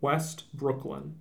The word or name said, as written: West Brooklyn